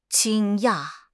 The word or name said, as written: 惊讶